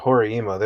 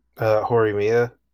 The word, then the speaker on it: Horimiya: That was the best part of Horiima. They were a couple by like the third episode. – Sorry, what? Which anime? – Horimiya.